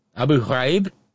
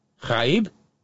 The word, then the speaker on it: Ghraib: That GH sound is it's kind of like a as well. Abu Ghraib? – Yeah. – Because the AI is not a diphthong. You pronounce each vowel I-E. Ghraib.